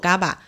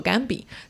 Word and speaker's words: GABA: 那么作者也有在文章里面提醒到我们说，虽然这个词它的拼音是 GABA，但是它并不应该被叫做或者念作 GABA。而是应该被念作甘比，但是我也有看到大量的科普读者其实都在叫它 GABA，后有很多商家也在叫它 GABA，所以我们接下来就以 GABA 这个名字来继续我们接下来的介绍。